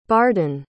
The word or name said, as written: barden